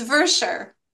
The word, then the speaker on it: Verschoor: It's Verschoor.